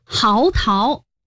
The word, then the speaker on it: 嚎啕: 还真的是嚎啕，号码的号，但是这里读作第二声嚎。